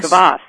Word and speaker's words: kvass: Is it pronounced kvass? Kvass? – Kvass, yes. Kvass.